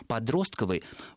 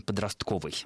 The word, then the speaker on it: подростковый: Подростковый, да, ударение на второй слог, произнести, скажем так, экономически менее выгодно, чем подростковый.